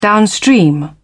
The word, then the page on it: downstream: British English